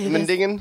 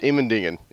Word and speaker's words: Immendingen: How do you say Immendingen? – This? – Immendingen. Immendingen.